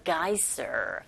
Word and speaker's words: geyser: 빈칸에 들어갈 말이 a geyser. Geyser, s 발음을 z로 하셔야 돼요.